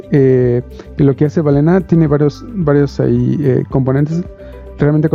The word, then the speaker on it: Balena: Ah, y este producto que estamos viendo eh, está interesante porque, bueno, no sé cómo, no recuerdo cómo lo pronuncian, es balina o balena. Es Balena Cloud y es para los entusiastas en el IoT.